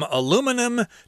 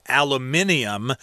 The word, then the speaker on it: aluminum: Aluminum is uh, how we say it in America. In England and other places, they'll say aluminium, but Americans say aluminum, which is a very light metal, which uh, a lot of uh, soda cans are made from.